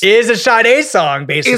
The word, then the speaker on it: Sade: Sade. Why did I say Sade? Wow. I did the Boston pronunciation.